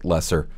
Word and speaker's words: Lesser: This release was spelled just LSR, but still pronounced Lesser.